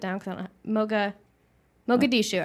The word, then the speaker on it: Mogadishu: That's where Black Hawk Down was, Mogadishu.